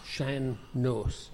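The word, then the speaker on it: sean-nós: But before, we'll say, the 40s, the typical dancing that you'd see in a house was what they call Shanos dancing. – How do you spell that? – S-E-A-N, I think. N-O-S. Shanos.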